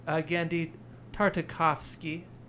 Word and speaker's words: Genndy Tartakovsky: So this movie, the other thing probably that should be said is just it's directed by, I think you pronounce his name, Genndy Tartakovsky. Let me see. Let me make sure I'm. – That sounded really good. – Right? Genndy Tartakovsky,